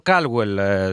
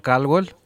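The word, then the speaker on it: Calwell: Βέβαια, εγώ το προφέρω Caldwell, Σωτήρη, δεν ξέρω πώς το προφέρεις εσύ, γιατί CA δεν μου κάνει O, αλλά τέλος πάντων. Λοιπόν, για πες, πού βρίσκεσαι ακριβώς στην έδρα Caldwell ή Caldwell?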